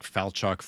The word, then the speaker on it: Falchuk: Like Falchuk, whatever.